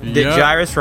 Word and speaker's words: Gyrus: He said on the live stream that Gyrus rhymes with Iris.